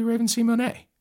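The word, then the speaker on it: Raven-Symoné: And there's an accent on the E. Should be Raven Simone.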